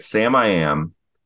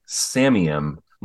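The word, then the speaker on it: Samiam: And, I always thought this band was pronounced the way I'm going to say it, but years ago, someone told me it's pronounced a different way, but I'm going to say it the way I say it. Sam I Am. – Yes. – Is that how? Okay. Someone once told me it's Samium.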